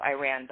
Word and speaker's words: Iran: So my parents are both from Iran, so Iran, but pronounced Iran correctly.